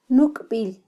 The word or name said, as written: nukbil